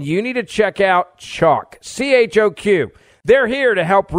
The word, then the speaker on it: Choq: Now, I've been taking Chalk, it's spelled C-H-O-Q, and for 2 weeks now, this is what I've been doing.